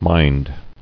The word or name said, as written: Minding